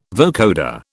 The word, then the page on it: vocoder: British English